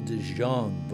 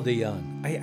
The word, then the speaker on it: De Jong: No, it's Paul DeJong. I get that wrong every time because it is spelled D-E-J-O-N-G. So being American, I think it's De Jong, but it's Paul De Jong, a Dutch dude from You Effin' Sucker, Weird Pipe Dream.